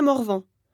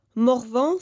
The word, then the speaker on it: Morvan: Alors Janssen, ça s'écrit J-A-N-2-S-E-N. – Bonjour, alors moi c'est Fatia, Fatia Morvan. Morvan, ça s'écrit M-O-R-V-A-N. – Eh bien moi, c'est André Lorieux. L-O-R-I-E-U-X.